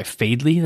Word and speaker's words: Faidley: One place is called Fadley. I think I'm pronouncing that right. F-A-I-D-L-E-Y. Fadley. That sounds probably right.